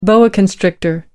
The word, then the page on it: boa constrictor: British English